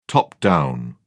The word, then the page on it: top-down: British English